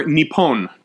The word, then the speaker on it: Nippon: In Japanese, you pronounce Japan as Nihon or Nippon, depending on the grammatical context around it.